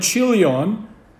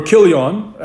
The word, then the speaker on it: Chilion: Now, does the fact that Mahlon and Chilion, or Kilion, as you spell it, married Moabite women indicate that they sinned against God?